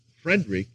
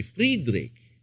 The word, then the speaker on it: Friedrich: In German, even to transliterate it, you would not pronounce it Frederick, you would say Friedrich, because that's the way it's pronounced in German.